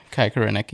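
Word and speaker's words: Koreniuk: And From the Galaxy Dos, who just came up, Kai Korenic, is that how you pronounce his name? – Yeah, Kai Korenic, yeah.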